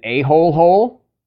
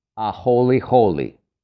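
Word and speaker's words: a-holy holy (ʻāholehole): If you ever see somebody write it down that they called you, like, an a-hole hole, it's not what you think. If when they write it down, if you read it as a-hole hole, well, think again, because it's actually pronounced a-holy holy.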